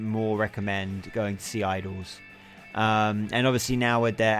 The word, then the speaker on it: IDLES: They're called Idols. It's spelled IDLES, not IDLOS.